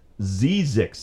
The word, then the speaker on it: Zzyzx: Yeah, what's It's pronounced Zizix. Z-E-I-Z-I-X. Zizix Road.